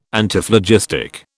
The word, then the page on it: Antiphlogistic: British English